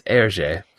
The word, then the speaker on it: Hergé: I discovered in preparing for this episode that I've been mispronouncing his name forever. – Herg? – I was going more with a Hergé, but apparently it's Hergé.